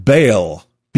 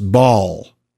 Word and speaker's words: Baal: Usually this was the Hebrew word Baal, B-A-A-L. I find it funny that in our sports we play ball. And actually, B-A-A-L is pronounced ball. You could say Baal, though, which means master or lord.